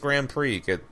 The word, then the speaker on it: Grand Prix: I like the Grand Prix. – That's how I normally would have said it, like, younger, too, because I keep forgetting that it's Grand Prix. The X is silent.